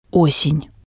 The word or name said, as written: osen